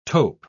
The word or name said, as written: taupe